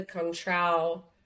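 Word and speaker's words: Cointreau: So just the tequila, the Contrao.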